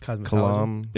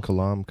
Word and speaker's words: Kalam: How did I say it? Did I say cosmological? It's Kalam. – It's cosmological. Kalam cosmological argument.